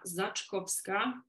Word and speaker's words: Zaczkowska: And for those who are listening for the first time, my name is Marta ZACZKOWSKA.